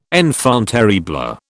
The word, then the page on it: enfant terrible: British English